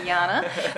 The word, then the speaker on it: Jana: Enjo, E N J O, which is probably probably why I love it because the J is pronounced yeah, just like my name, Jana.